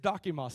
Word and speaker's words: dokimos: The Greek word there is dokimos. Say dokimos. Come on, say it like you're a Greek philosopher. Dokimos. Okay.